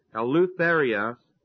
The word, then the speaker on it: eleutheria: First of all, simply saying, O ye Corinthians, our mouth is open unto you, is a figure of speech called eleutheria. It's spelled E-L-E-U-T-H-E-R-I-A, Eleutheria.